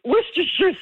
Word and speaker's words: Worcestershire: The name of this fermented English fish sauce is notoriously difficult to pronounce, thanks largely to the silent C in its name. What is this sauce called? – Do I have to pronounce it right? Worcestershire sauce.